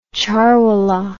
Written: charwallah

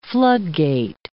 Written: floodgate

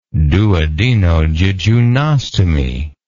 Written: duodenojejunostomy

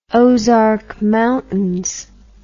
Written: Ozark Mountains

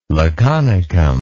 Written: laconicum

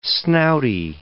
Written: snouty

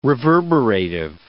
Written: reverberative

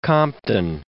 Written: Compton

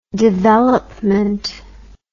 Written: developement

